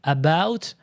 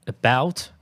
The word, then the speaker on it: about: Et euh, c'est pour ça que, que, nous, les Français, on a l'air complètement con quand on dit about. C'est en fait, c'est about. En fait, on prononce presque pas le E.